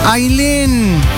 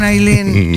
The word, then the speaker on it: Ailén: ¿Se escribe A-Y-L-E-N? – No, mi nombre es uno de los pocos que conozco que se escriben A-I latina doble L-E-N. Se escribe Ailén y se dice Ailén.